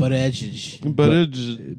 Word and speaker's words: Buttigieg: Pete Buttigieg. – Yeah, is that how you pronounce it? – Buttigieg.